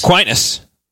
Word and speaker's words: Aquinas: Is it Aquinas? – Aquinas.